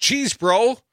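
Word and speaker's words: Chesebro: Cheesebro, pronounced Cheesebro, after the family origins in the English borough on the River Chaise.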